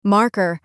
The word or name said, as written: marker